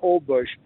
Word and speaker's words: Holbox: H-O-L-B-O-X. It's pronounced Old Bush.